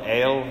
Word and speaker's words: El: That can only tell us up to the point of the word God, which in Hebrew is El, E-L, El, it's pronounced El.